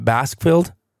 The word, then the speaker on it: Baskfield: So your last name is, do I pronounce this right, Baskfield? – Correct, Baskfield.